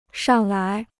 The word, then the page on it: 上来: shàng lái